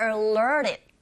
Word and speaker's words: alerted: 그렇지만 부정인지라 길게 발음하시고, isn't alerted. 여기서도 t 사운드 자체가 rt가 모음과 모음 사이 자리 잡고 있어서 이 flap 현상에 의해서 들리는 소리는 t가 아닌 r. 그래서 alerted, alerted by strangers. 이렇게 발음하시면 돼요.